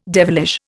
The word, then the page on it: devilish: Indian